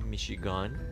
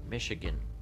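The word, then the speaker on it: Michigan: ما میگیم میشیگان آنچه ما میگوییم میشیگان آنچه آنان میگویند میشیگن گن G-A-N آخرش نوشته میشه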